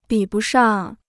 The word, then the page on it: 比不上: bǐ bù shàng